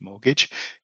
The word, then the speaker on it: mortgage: And in actual fact, when you spell it, it's spelt M-O-R-T and then gidge or gauge or gidge, mortgage, because it's said quite quickly, the second bit. So it's mortgage. But the R is silent in this case. So it's actually sounds like mort, gauge, if you look at it spelt, but it's actually pronounced mortgage. So the R is silent in it.